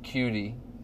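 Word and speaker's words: Coutee: His name's QT. That's literally how you pronounce it, QT. – Yeah, his first name's Keek. K-E-K-E.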